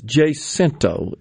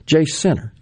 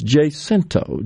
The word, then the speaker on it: Jacinto: Now, yesterday, the candidates made their way up to Jacinto, Alcorn County. I think that's where we finally settled. That's where it is, right? So my good friend Nick Bain, who represents the area, says, yeah, it's Jacinto is the proper pronunciation.